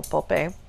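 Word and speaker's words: Popé: It's spelled like Pope, it just has an accent over the E, so Pope.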